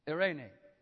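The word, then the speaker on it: eirene: In the New Testament, it's Irene. It's spelled E-I phonetically, R-E-N-E, okay? Irene.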